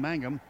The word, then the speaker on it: Mangum: The 27-yard field goal by Dusty Mangum, to correctly pronounce his name, Mangum, who is from Mesquite, Texas, a walk-on.